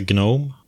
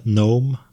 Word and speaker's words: GNOME: Sen finns det Gnome, eller Gnome, beroende på vilken skola man tillhör, att man uttalar det.